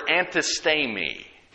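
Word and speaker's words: antistemi (ἀνθίστημι): The word withstand is the Greek word antistemi. And it almost sounds like the word antihistamine.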